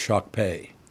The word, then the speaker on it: Shakopee: Why did they add the O to that word, by the way? Shakopee. – You think it should be Shakpee? – It's Shakpee. – Shakpee? – Is how you announce it. pronounce that. – Oh, like why did they spelled it the way that you should spell it? But you're saying we add the O in the word. – Yeah, no, there's no O.